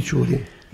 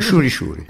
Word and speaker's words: ciuri: Ciuri ciuri. Si pronuncia così, ciuri, no, non Shuri Shuri.